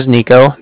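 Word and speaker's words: Niko: Again, my name is Nico. That's N-I-K-O. And I was calling with the Bank of Cardiff. Cardiff is spelled C-A-R-D-I-F-F, as in Frank.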